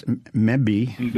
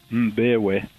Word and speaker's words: Mbewe: Sproul, Steve Larson, Conrad, I don't know how to pronounce this, Mbewe. – Say again? – Mbewe. – Mbewe, okay. it's spelled M-B-E-W-E, folks, so you can see I might have a problem with that.